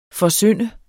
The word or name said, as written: forsynde